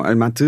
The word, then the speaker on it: Almaty: Genau, Almaty, es ist eigentlich ganz auf Kasachisch ausgesprochen, aber der internationale Name war Alma-Ata und ich bin in einem Vorort in Kaskelen geboren.